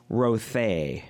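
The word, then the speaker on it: rothé: Oh, apparently we're pronouncing it wrong. – Oh, okay. – Surprise. – Rothay. – Rothay. Oh, I don't do French.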